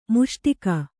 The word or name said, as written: ಮುಸುಡಿಕ್ಕು